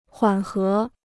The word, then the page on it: 缓和: huǎn hé